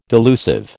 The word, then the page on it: delusive: American English